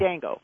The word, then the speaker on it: Django: Is the D silent or the J silent? Is it Django or is it just Django? I don't know. – Django. – It is? Okay. – The D is silent.